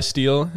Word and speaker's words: Steele: My name is Levi Steele. My last name rhymes with Peel and other stuff.